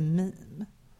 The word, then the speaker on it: meme: Jag vet ju vad mems, vadå? Vad, det heter det väl? Och de bara, nej, det heter meme.